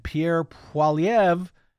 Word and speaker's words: Pierre Poilievre: And Pierre Poilievre, I said it right, and his Conservative Party, they were all set to absolutely clean the Liberal Party's clock.